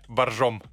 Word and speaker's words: Боржоми: Боржоми или боржом? Пом- помните в э, служебном романе «Боржом»? – А, ну, наверное, правильнее, конечно, произносить, если мы а, а, окунемся в те исторические дали. Угу. Наверное, боржом, но боржоми.